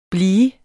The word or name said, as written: blivende